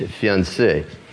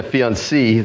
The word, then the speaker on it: fiancé: And even though there's an extra E added on for the girl, they are both pronounced the same in the French, fiancé. You don't have a fiancé and a fiancée. They're both fiancé, right then?